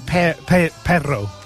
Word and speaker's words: perro: So I just, but basically she said, so say perro. So I said perro. – So you can roll your R's.